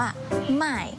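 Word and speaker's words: 卖: ส่วนคำว่าขายภาษาจีนนะคะจะออกเสียงคล้ายกับคำว่าซื้อหมายเลยแต่ว่ามันจะออกเสียงต่างกันนิดหน่อยขายภาษาจีนออกเสียงว่าหมาย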